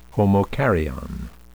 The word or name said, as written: homokaryon